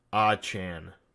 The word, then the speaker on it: Achane: Achane, apparently after this game, he felt enough confidence to tell the coaching staff and the team that apparently his last name is pronounced Achane, which doesn't sound as fun. But either way, that's how it's pronounced, so I'm going to try to respect that.